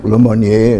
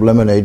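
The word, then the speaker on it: Lemonnier: It used to be called Camp Le Monnier. It's called Camp Lemonade because they can't pronounce it in French.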